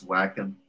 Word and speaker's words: Wacom: I was able to afford to buy Wacom, but I think the actual pronunciation is Wacom tablets, and they have like the screen, the monitor screen on it.